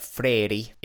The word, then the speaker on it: Freire: I've heard it pronounced as Freire, Freire, etc. But Freire is how multiple people who live in Brazil actually pronounce the name, so I assume it is correct.